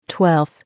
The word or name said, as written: 12th